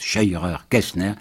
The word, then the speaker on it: Scheurer-Kestner: Il a choisi M. Scheurer-Kestner, prononciation exacte Scheurer-Kestner, qui est vraiment insoupçonnable puisqu'il a été député protestataire.